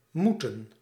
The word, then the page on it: moeten: /ˈmu.tə(n)/; /mu.tǝⁿ/